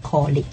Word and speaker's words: colleague: Pronunciation here is, you don't say college or click. I'd like to introduce you to my colleague.